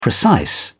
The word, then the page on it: precise: British English